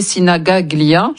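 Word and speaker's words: Sinigaglia: C'est le stade Giuseppe Sinagaglia. J'espère que j'ai bien prononcé, Matteo. – C'est bien. Matteo, c'est bien. – C'est bien, c'est très bien.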